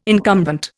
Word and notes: incumbent: Indian

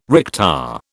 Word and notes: recta: British English

